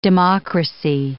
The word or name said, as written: democracy